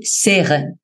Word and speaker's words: Serres: En francés se pronuncia Michel Serres, sin la S al final, Serres, pero como hablamos español, vamos a decirle Michel Serres, como usted le dice, o Serres.